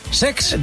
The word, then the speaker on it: Cesc: Lo he dicho mal, es Sex.